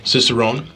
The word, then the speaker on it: Cicerone: Cicerone.